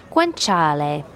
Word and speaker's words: guanciale: Guanciale.